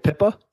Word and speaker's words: Pippa: I've been calling it Pippa. – Okay. – Like the Princess's Sister.